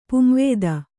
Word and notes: ಪುಂವೇದ: kannada

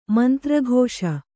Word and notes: ಮಂತ್ರಘೋಷ: kannada

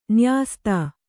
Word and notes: ನ್ಯಸ್ತ: nyasta

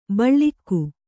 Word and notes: ಬಳಗ: kannada

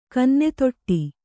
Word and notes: ಕನ್ನೆತೊಟ್ಟಿ: kannada

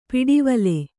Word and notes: ಪಿಡಿವಲೆ: piḍivale

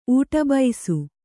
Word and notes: ಊಟಬೈಸು: ūṭabaisu

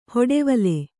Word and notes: ಹೊಡೆವಲೆ: kannada

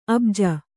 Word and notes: ಅಭಿಜೆ: abhije

